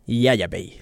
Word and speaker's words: Yaya Bey: Yaya Bey, sí. Así me dice don Jaime que se pronuncia.